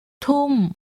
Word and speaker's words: ทุ่ม: Repeat using a falling tone. – ทุ่มทุ่มทุ่ม How would she say at 9 at night?